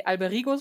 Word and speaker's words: Alberigo: Ähm, in dem, ja, wie soll man es nennen, im dritten Abschnitt, also in der Ptolemäer, sitzt Bruder Albericho oder Al nee, Alberigo spricht man es, glaube ich.